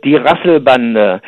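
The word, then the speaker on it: die Rasselbande: Yeah, in German it means, well, you pronounce it die Rassel Bande.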